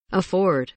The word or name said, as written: afford